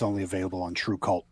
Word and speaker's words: TRVE KVLT: And just to clarify for anybody listening who wasn't watching earlier, it's spelled TRVEKVLT, but pronounced True Cult, right?